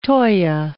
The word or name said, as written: toea